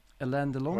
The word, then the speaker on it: Alain Delon: Alain Delon? – What's that? – How do you pronounce it? Alain Delon? – Oh, yes. Alain Delon.